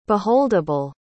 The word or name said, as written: beholdable